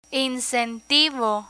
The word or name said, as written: incentivo